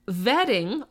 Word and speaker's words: Wedding: Vetting, oh, thank you, Lee. He literally put in pronounced vetting. Thank you, because it's spelled wedding.